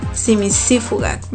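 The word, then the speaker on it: cimicífuga: Simisífuga, va con C de casa, las dos, simisífuga. Lleva acento en la tercera I, simisífuga.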